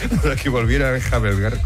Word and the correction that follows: enjalbegar: ¿cómo se dice? Enjalbegar la... – Enjalbegar la pared.